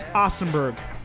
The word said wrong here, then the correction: Osterberg: Can you pronounce his last name again? – Ostenberg? – I'll give it to you. James Osterberg Jr. Thank you.